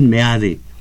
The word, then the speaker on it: Meade: Eso, MEADE, nada de mí su pronunciación es MEADE mi gobierno llevará prosperidad a los hogares mexicanos.